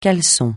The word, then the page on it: caleçon: francais